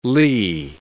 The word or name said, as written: lea